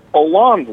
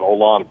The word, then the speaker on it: Hollande: I mean, Hollande wasn't very. This is the president. Holland is how it's, but Hollande, how you say it in French.